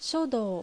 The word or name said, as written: Shodou